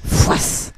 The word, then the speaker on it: Foïs: Foïs. – Foïs, ouais. – C'est bon, hein. – Ça s'écrit comment? – F-O-I-S, avec un tréma sur le i.